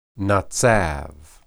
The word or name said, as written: natsab